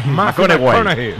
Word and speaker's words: McConaughey: Matthew McConaughey. – Sí. – Es un futbolero, le gusta. Que hay que ir corrigiendo la pronunciación. – McConaughey.